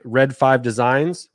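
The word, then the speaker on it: Read 5 Designs: And again, all you have to do is tag Red 5 Designs, which is spelled Reed 5. – R-E-A-D. – R-E-A-D 5 Designs.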